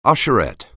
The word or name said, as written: usherette